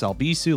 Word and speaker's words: Albisu: You can find me at Alex Albisu. Last name is spelled A-L-B-I-S-U.